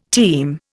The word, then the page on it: team: Indian